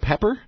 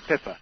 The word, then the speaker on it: Pippa: Your wife is called Pepper? – Pip. Well, it's very hard for the North Americans to say. It's spelled P-I-P-P-A. It's Pippa. – It's a female version of Philippa.